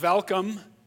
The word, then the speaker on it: Welkom: It's the city of Welkom, pronounced with a V, but it's spelled Welcome with a W, so it's the whole German pronunciation.